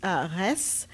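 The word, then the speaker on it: Reims: Yeah, I fell in love with Res. Some people pronounce it Rem. It's R-E-I-M-S, which is the capital of Champagne.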